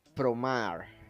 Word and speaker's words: Promare: Bueno, esto no, es una película que se llama Promar. Ok, se escribe Promar. – Promare. Se escribe.